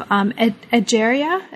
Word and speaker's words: Egeria: Egeria.